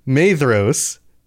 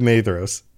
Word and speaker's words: Maedhros: And in the confusion that they wrought, they came near to the standard of Maedros. And I just realized I've been doing it again. Pronunciation is rough. Maedhros—it's It's a DH. I talked about this on the last episode, and yet I forgot. Maedhros, by the standard of Maedhros.